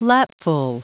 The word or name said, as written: lapful